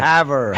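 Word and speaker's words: Havre: He's actually the former editor-in-chief of the – how do you say that town? – Haver. – Haver. Sorry, I don't come from small town Montana. But the Haver – it's French.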